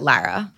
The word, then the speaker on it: Lara: Tell me how you pronounce your name, just so that we can listen. – I pronounce it Lara.